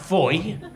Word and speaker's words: Fowey: They can't spell it Fowey and then go, "Oh, but it's pronounced Foy."